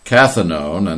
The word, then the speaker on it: cathinone: Cathinone, and that's pronounced cathinone, derivatives have been known to produce a substance-induced psychosis.